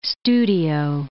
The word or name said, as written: studio